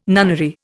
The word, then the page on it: nunnery: Indian